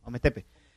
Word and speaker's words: Ometepe: Entonces, bueno, más o menos saber cómo lo habían logrado, y el comentario va con respecto a Ometepe. ¿Lo dije bien? Sí, Ometepe.